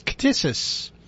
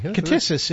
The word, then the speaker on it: ktisis: Well, there's a word that is commonly used, and it's a hard one to pronounce. It almost sounds like you're trying to tickle somebody. It's ktisis. – Oh, excuse me. – Ktisis is the noun.